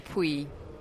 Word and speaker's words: Puig: It's Luca Puig. – So I was right, Jeffrey. – I was much closer. – No. I'm afraid you weren't. You said you pronounced the L's.